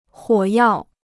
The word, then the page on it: 火药: huǒ yào